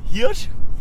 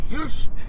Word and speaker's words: Hirsch: Oder wie Sebastian Holm mich sagt, Leila Hirsch. – Hirsch. Das muss deutsch ausgesprochen werden. Das ist ein Hirsch. Das ist ein stolzes Tier.